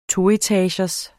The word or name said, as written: toetagers